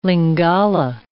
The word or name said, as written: Lingala